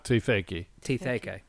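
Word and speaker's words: Te Wheke: I'm not overly familiar with Maori culture or pronunciations, but Te Wake. – Te Whaki. – Sorry. I'm going off of phonetic reading with my silly colonial reading. So it's Te Whaki.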